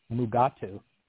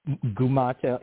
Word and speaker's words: Mugato: You didn't even talk about the fact that every single time they pronounce Mugato, they mispronounce it, except maybe like one time. So every time they say the name, they say it different. Shax in particular literally says Gumato, and like every version about six or seven times throughout the entire episode, they just cannot get it right.